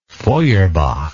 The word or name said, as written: Feuerbach